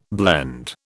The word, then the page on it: blend: British English